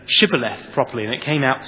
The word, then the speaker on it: Shibboleth: If he replied, "No," they said, "All right, say Shibboleth." And if he said Sibboleth, because he couldn't pronounce the word correctly, they seized him and killed him on the fords of the Jordan.